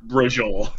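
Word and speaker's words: braciole: but I want to remember how to pronounce it. And what I ended up with was BRAJOLE, pork brujol.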